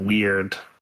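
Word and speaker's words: Wyrd: And then I did want to mention there was a game called Hellboy Web of Weird. I think that's how it's pronounced. It's W-Y-R-D.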